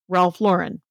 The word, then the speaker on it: Ralph Lauren: And is a fan of Ralph Lauren, who I am told is pronounced Ralph Lauren. – Really? Lauren, not Lauren.